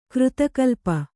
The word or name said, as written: ಕೃತಕಲ್ಪ